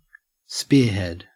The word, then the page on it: spearhead: Australian English